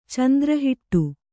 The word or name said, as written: ಚಂದ್ರಹಿಟ್ಟು